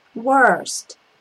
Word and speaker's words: worst: The sound R can be difficult for pronunciation, but we should practice. Worst. The same as in first. Girl. Turn. Learn.